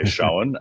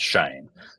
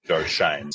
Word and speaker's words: Schoen: Some say Schoen, but the Giants' official website says that it is pronounced Shane, Joe Shane. So we'll go with Joe Shane.